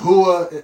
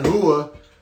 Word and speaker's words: Rua: And yes, it's spelled Rua, R-U-A. In Brazil, they pronounce R's like Mexicans pronounce J's. So like Rua instead of Rua,